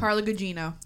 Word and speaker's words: Gugino: Carla Gugino.